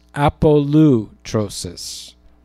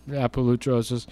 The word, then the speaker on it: apolutrosis: I think this word is pronounced apolutrosis. APOLUTROSIS. I used to call it apolutrosis,